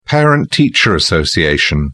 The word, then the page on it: parent teacher association: British English